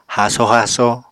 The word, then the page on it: haaso haaso: Apatani